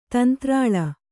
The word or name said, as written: ತಂತ್ರಾಳ